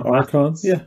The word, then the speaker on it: Archon: The office of Archon. Is that how you say it in English? – Office of... – The Archon. – Yeah, Archon. That's how I would say it.